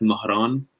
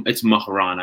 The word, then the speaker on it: Mihran: My name is Persian. It's pronounced in the dialect Mahran. So it's Mahran. I say Mahran with no H, no K in the middle. It's just an H.